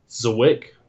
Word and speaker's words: Zwick: Zwick. – How do you spell it? – Z-W-I-C-K.